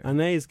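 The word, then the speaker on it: Anaïs: How do you pronounce her name? – Anais Gallagher. – Anais Gallagher. – Mayonnaise. – Mayonnaise Gallagher.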